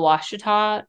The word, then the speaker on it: Ouachita: And for those of you guys that don't know how to spell Washita, it's kind of spelled weird. It's spelled O-U-A-C-H-I-T-A, Washita.